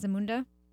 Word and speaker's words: Zamunda: Am I saying that right? Zamunda? – Yeah. I believe so, yeah.